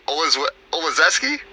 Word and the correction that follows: Olszewski: Olszewski. – Oh, yeah, I was off. Olszewski and Mary Riva, they finished second.